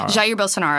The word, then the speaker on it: Jair Bolsonaro: Jair Bolsonaro.